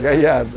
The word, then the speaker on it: Gallardo: How do you pronounce it? – Gallardo. – You say Gallardo.